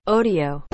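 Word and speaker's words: Odeo: chama Odeio. – ódio, odeio, enfim. – É O-D-E-O. – Odio.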